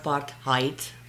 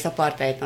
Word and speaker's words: apartheid: It's Afrikaans, and it's pronounced apartheid. By the way, not apartheid. But whatever. – Sorry. – No, I'm talking to everyone. – Blasphemy. – They need to say it right. It's apartheid, not apartheid.